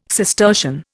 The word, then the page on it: Cistercian: Indian